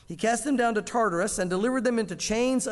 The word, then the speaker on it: Tartarus: Verse 4 of chapter 2, "For if God did not spare the angels who sinned, but cast them down to Tartarus," or Tartarus is probably the proper way to say it.